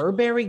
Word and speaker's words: Burberry: They're Burberry glasses? – Yeah, Burberry's. – How do you pronounce Burberry? – Berbers. Why? – Yeah, it's not Berbers. It's Burberry.